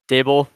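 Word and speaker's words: Daboll: Am I saying that right? – Dable.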